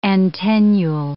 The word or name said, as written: antennule